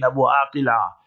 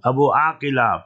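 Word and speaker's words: Abu Akleh: Who can forget Shirin Abu Aqila? Actually wrong, Abu Aqila, pronounce the name properly.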